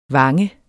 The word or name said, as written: vange